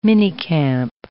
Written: minicamp

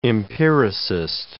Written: empiricist

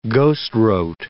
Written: ghostwrote

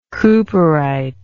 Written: cooperite

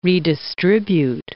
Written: redistribute

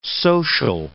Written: social

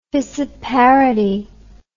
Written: fissiparity

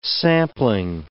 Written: sampling

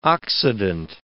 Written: oxidant

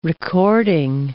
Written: recording

